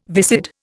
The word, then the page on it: viscid: Indian